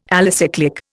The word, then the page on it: alicyclic: Indian